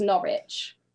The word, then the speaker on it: Norwich: Right, they're on the opposite sides of the country, and it's Norwich.